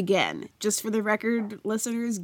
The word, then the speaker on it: Gen: So I pronounce it Gen because of my knowledge of classical Greek, that I think that that is how that should be pronounced. But there's really no rules.